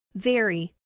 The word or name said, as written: very